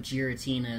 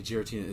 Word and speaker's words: Giratina: I've never heard anyone say Giratina. It's Giratina. The gear because it's a gear.